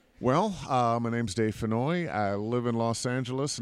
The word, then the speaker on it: Fennoy: We're with Con Air Radio, and I have the amazing opportunity to talk to one of the most awesome voice actors ever, Dave Fennell, I believe is how it's pronounced. – Dave Fennoy. – Fennell. Okay, I was close. – Yeah, it's like Annoy, only Fennoy.